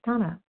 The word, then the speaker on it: Dana: This is Donna B. I'm a recovered compulsive overeater and bulimic. I'm calling from Denver. My name is spelled D-A-N-A, but pronounced Donna.